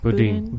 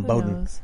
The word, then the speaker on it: Boudin: How do you pronounce it? – Boudin. – Boudin? I was calling him Bowden.